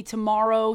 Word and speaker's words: TMRW: They announced this new company, Tomorrow Sports, and by tomorrow it's spelled TMRW Sports.